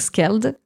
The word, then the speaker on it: Skald: Alors même, je pourrais le prononcer à la norvégienne, donc Skald, qui est un groupe français, contrairement à ce que son nom indique, okay.